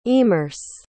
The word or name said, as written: emerse